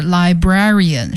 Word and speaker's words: librarian: Hello everybody, today we are going to listen to a librarian talking about her strategy to deal with overdue book fines. 这一下出来一些和图书馆有关的词，第一个呢就是 librarian，是图书管理员。 – 这个读音和 library 有点像，但是呢很多同学都不太能够100%的发音准确哈，我们再给大家读一下这个图书管理员的英文单词 librarian，所以它重音是在 bra 这个音节上 librarian。